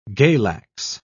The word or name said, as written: galax